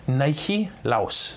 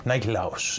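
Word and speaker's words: Nikolaos: Nike Laos. That's how you say his name. Nike Laos in Greek.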